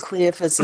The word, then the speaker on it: Cleophas: How do you say that? – I think Cleophas is how I right. heard it, yeah.